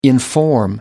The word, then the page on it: inform: American English